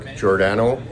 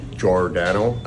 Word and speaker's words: Giordano: Hi, my name is Mark Giordano, pronounced Mark Giordano. I only have one nickname. It's Gio. – Mark Giordano, not Giordano. – I always said Giordano. – Yeah. – Like jeering somebody. J-O-R, Jordan.